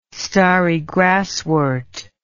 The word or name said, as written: starry grasswort